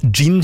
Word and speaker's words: jinn: Gin tonic, ja. Nei, ja, det skrives med jod. J-I-N-N. Gin. Men okay. Også det uttales gin.